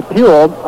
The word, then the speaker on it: Puhl: That triple by Poole, his name is spelled P-U-H-L. The triple by Poole means that he has batted safely now in 8 straight ball games and 11 of the last 12.